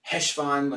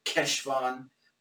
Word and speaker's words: Heshvan: Heshvan, from what I understand, it's K, as in the Germanic CH, like Bach. – Yeah. – So it's a Heshvan. So some people just say Heshvan when they're saying it quickly with an H. Some people actually pronounce it with a solid Keshvan or something like that.